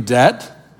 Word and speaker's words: debt: I'm obligated is the word in debt. I'm in debt. Is that a B? Yeah, I'm not really. That silent B.